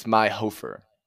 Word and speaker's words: Meihofer: It's Meihofer.